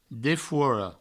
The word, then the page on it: defora: Provençal; defouoro